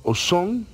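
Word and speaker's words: Ausone: Actualmente hay cuatro vinos importantes en el primer eslabón, que sería el Chateau Cheval Blanc, el Chateau Ozon, que se escribe Ausoné, eh, y, si lo ven algún día lo, puedan identificar.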